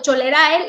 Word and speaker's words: cholerae: Exacto, cholerae, eh, la, la pronunciación correcta.